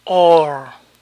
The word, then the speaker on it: oar: Remo se pronuncia or, or. Eso quiere decir que la OAR al final de estas palabras se pronuncian or. Or, roar, bore, soar.